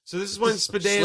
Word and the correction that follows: Stepanek: Spadanek. Am I saying that right? Spadanek? Spadanek? – Stepanek. – Stepanek. God damn.